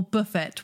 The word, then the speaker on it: Buffett: Those first two tracks that you released from, is it Jimmy Buffet or Buffett? How would you say it? – Jimmy Buffett.